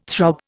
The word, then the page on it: throb: Indian